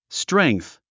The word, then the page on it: strength: stréŋ k θ; ストレェングス